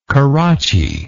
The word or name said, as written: carracci